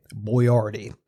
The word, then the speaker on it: Boiardi: His name is spelled B O I A R D I, Boyardee.